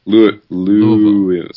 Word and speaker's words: Louisville: I've been told Louisville. – Louisville. – So Louisville. Okay. Skip right over that I. Just pretend it's not there.